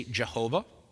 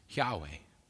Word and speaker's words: Yahweh: Hebrew has no J sound, so I can just say clearly that's not the right pronunciation. That's just an Englishized version of it. Scholars, when they talk about this, there's kind of a general agreement that probably the most likely pronunciation is Yahweh.